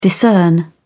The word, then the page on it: discern: British English